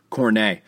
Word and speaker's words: Kornet: Kornet, the T is silent. I'm not sure if you guys know that. Soft T, Kornet, Luke Kornet.